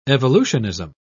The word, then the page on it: evolutionism: British English